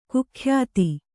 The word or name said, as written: ಕುಖ್ಯಾತ